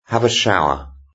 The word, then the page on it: have a shower: British English